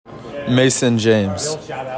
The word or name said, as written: MASON JAMES